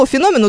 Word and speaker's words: феномен: В русском языке у слова «феномен» ударение на букву «о».